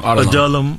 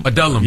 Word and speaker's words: Adullam: You think about this this film, The Cave of I always pronounce it wrong. Adullam. Adullam. Yes. The Cave of Adullam.